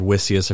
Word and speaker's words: Witsius: Anyway, I can't recommend to you highly enough, and it's not that we agree with everything this man says, but Herman Vissius, W-I-T-S-I-U-S, Herman Vissius, I've heard Wissius, I've heard Vissius. Generally, the Dutch names, the W is pronounced as a V, but I've been corrected on that the other way. Either way, Herman Vissius, that's what we're going to call him.